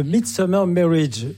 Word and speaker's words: The Midsummer Marriage: C'est The Midsummer Marriage. J'ai mal prononcé tout à l'heure.